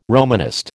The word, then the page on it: Romanist: American English